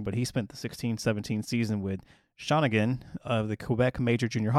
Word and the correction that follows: Shawinigan: That's, that's It's Shawinigan. – Oh, Shawinigan. that sounds way more Canadian than Shawnigan.